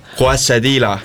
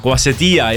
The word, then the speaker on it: quesadilla: Õpetame nüüd siin hääldama aga quesadilla. – Quesadilla. – Ei jälle jääda, quesadilla ja tortilla, mitte tortilla ja quesadilla.